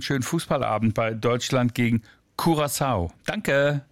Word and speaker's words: Curaçao: Also richtige Aussprache, Curaçao. – Wenn man es wie die Inselbewohner machen möchte, genau. Curaçao wäre die niederländische Variante, habe ich aber auch oft dort gehört, geht beides.